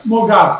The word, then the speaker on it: Moghaddam: Nader, I always butcher your last name. That's why I didn't say it. Would you say your last name for me? – Moghaddam.